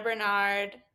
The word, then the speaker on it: Barnard: So you can follow Jenna Barnard.